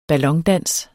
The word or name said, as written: ballondans